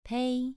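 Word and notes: pei: tone 2

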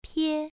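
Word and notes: pie: tone 1

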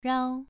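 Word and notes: rao: tone 2

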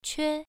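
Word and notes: que: tone 1

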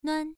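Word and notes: nuan: tone 1

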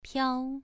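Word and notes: piao: tone 2

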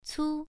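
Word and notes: cu: tone 1

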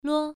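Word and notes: luo: tone 1

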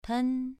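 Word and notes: pen: tone 2

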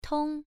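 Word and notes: tong: tone 1

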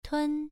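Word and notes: tun: tone 1

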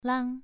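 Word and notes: lang: tone 2

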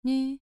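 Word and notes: nv: tone 2

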